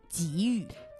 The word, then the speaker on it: 给予: 这个时候呢，它就读作三声，正确的读音是给予。